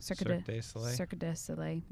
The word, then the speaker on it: Cirque du Soleil: So for part of Zeke's Christmas, we decided to take him to IU for, they had a Cirque du Soleil. I think I might be saying that wrong. – Cirque du Soleil, whatever.